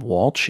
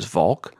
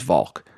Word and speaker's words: Walch: I know neither are ideal, but reality is my name is Rob Walsh is how I say it to Siri and it finds it. But if I was to pronounce my name correctly, which is Volk, it wouldn't find my name correctly. So, I mean, the correct pronunciation of my last name is Volk, but it's going to be looking for V-A-L-K and not W-A-L-C-H.